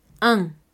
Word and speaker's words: un: Para estos primeros números, recordemos que el número 1 se escribe U-N. pero a pesar de tener la U, no vamos a conservar el sonido de U, lo vamos a convertir en una nasal, es por eso que decimos ON.